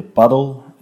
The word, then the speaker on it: padel: In het Spaans wordt het eerder padel uitgesproken. De alternatieven voor padel klinken alleszins minder mooi dan padel. Ja, paddle of paddle.